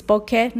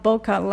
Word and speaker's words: bokeh: The word is bokeh, not bokeh, like Phil Schiller and lots of photography people mispronounce it. E is always e in Japanese.